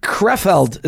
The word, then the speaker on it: Krefeld: And ladies and gents, please join me in welcoming Rabbi Yitzchak Wagner, who is the shliach of the Rebbe to the city Krefeld. Is that correct? Is that how you pronounce it? – Yeah, you made a very good job. Even though most people can't pronounce it correctly, if it's hard for you, I always say you can call it Kreplach.